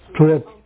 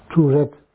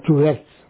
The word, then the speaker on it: Tourette's: So then, like, say, we say, Tourette. How do you say? – Tourette. – Yeah. – Tourette's. – Yeah, with an S on it. Yeah. Tourette's.